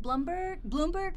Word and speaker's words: Blumberg: I think he says it Bloomberg, but it's spelled Blumberg. – Isn't he the Planet Money L-U-M.